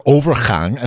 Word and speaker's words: overgang: Or overgang. – Overgang, yes. She was talking with some other worker about her overgang, and then I looked it up, and I'm like, what?